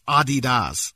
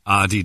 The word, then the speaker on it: Adidas: Americans pronounce Adidas wrong. It's Adidas.